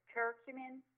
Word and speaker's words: curcumin: Curcumin, C-U-R-C-U-M as in Mary, I-N as in Nancy.